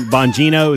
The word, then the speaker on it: Bongino: By the way, so Dan Bongino is how you say his name.